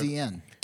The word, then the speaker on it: DAZN: What's DAZN? – So DZN is a streaming service where you can watch fights, right? Okay. Yeah, you watched it. You said it right.